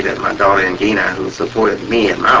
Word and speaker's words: Ngina: and they've called Chicago and asked for her, Angina, N-G-I-N-A, pronounced Angina.